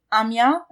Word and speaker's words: Amiens: And then the other time that you were studying French was in, is it Amiens? Is that how you pronounce it? – Yes. – Okay. – Perfect pronunciation, actually. So, yeah.